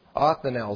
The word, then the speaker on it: Othniel: Othniel is how you pronounce it.